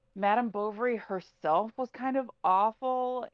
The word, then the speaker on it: Bovary: but Madame Bovary, like just the title is so off-putting to a teenage boy. Like it rhymes with ovaries, it's Madame, right.